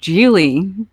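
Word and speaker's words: Gigli: The movie is the 2000 Ben Affleck, Jennifer Lopez joint, Geely, as in rhymes with really.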